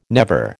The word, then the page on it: never: American English